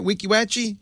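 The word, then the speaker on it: Weeki Wachee: Is that right? Am I saying it right? Wekiwatchee? – You certainly are, yes.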